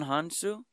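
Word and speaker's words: Hounsou: Demon Hansu? – Yeah. Because his last name is H-O-U-N, which was Han. S-O-U. Hansu. – I always pronounce his name Demon... Dumanji? – Dumanji. It's D-J-I, so Jaimon.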